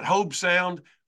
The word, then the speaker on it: Hobe Sound: Hobie Sound? How do you how do you pronounce that? Hobe Sound, Florida,